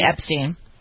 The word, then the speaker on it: Epstein: Is it Steen or Stein? I don't know how to pronounce that. That's Epstein. – Epstein.